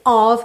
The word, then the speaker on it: of: E lembra que esse of, que se escreve OF, sempre tem som de OV, ok?